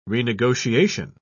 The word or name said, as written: renegotiation